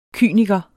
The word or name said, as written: kyniker